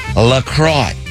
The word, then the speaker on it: La Croix: Pronounced La Croix.